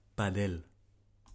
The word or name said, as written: Padel Hispano